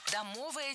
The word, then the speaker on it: домовая: Домовая территория. И это правильно. Чаще я слышу вариант с ударением на окончании, но он неверный. Литературная норма только домовый.